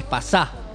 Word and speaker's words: pasá: Es que lo nuestro no tendría que tener un acento, porque es pasá. – Claro. – Tendría que tener acento en la A, pero bueno, por una cuestión de estética, nada más.